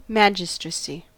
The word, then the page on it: magistracy: American English